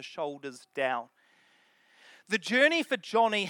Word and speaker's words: Joni: I always pronounced her name wrong. I said Joni, but I did some research. It's actually Joni after her father, John.